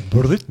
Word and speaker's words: Breut: Tu prononces le T, toi Oui. – Hmm tu dis pas, toi Non. Tu dis plutôt Françoise Brut.